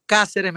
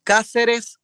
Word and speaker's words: Cáceres: así que me van a escuchar como Cáceres, me van a encontrar como Cáceres, se escribe C-A-C-E-R-E-S, Cáceres Música en todas las plataformas,